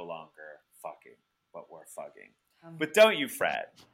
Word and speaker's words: Fugging: So a campaign to change the village's name to Fugging, right? F-U-G-G-I-N-G, which actually it would be pronounced the same way in the local dialect, right?